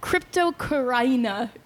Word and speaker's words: Cryptocoryne: I think Cryptocoryne is the like technical pronunciation of the word, but man, it's really hard to get yourself to say that every time.